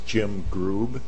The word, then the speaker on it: Grube: For the record, my name is Jim Grube. That's spelled G-R-U-B as in boy, E.